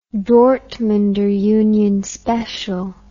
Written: dortmunder union special

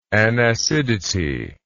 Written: anacidity

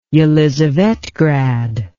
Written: Yelizavetgrad

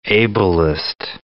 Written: ableist

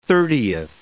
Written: thirtieth

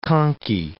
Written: conky

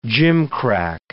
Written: gimcrack

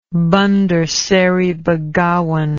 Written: bandar seri begawan

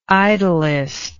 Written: idyllist

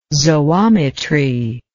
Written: zoometry

